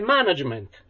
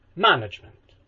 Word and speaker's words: management: Come ne sbaglia un'altra, che è management. Dove saremmo nello sdrucciolo, invece noi lo pronunciamo piano.